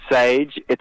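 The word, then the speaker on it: Saije: And, Well, so we're Sage. The name sounds very simple. Like, it's pronounced like the herb, but it's spelt strangely. It's S-A-I-J-E.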